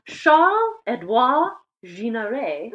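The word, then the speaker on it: Charles-Édouard Jeanneret: Le Corbusier was born Charles-Édouard Ginaret.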